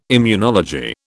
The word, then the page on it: immunology: British English